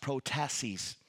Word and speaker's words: prothesis: As a matter of fact, in Greek, the word purpose is pronounced prothesis, which means to be prepared in advance.